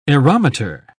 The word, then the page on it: aerometer: British English